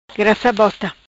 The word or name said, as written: Grassa Bota